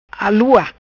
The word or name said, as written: Alua